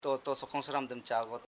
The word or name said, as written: to.so.ro